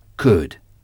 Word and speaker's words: could: Could. I can. Je peux. I could. Je pouvais. Ou j'ai pu. I could. Could. Could s'écrit C-O-U-L-D. Mais le L ne se prononce pas.